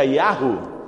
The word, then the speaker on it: Yahoo: Si Cristo recorre tu bandeja de entrada en el correo de Hotmail o de Gmail o de Yahoo, dicen en unas partes, pero eso como que en inglés se pronuncia Yahoo.